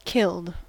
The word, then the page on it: killed: American English